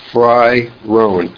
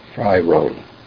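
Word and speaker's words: Frey-Rohn: A wonderful book, if you want to buy yourself a Christmas present, is Liliana Fry Rohn, F-R-E-Y hyphen R-O-H-N, From Freud to Jung. – Spell it again, please. – Fry, F-R-E-Y. Hyphen, wait, sorry. Yeah, Frey hyphen Rohn. Fry Rohn.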